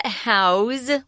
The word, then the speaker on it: house: I wanted to point out, we pronounce the verb house with a Z sound, but if it's a noun and you're talking about someone's house, it's an S.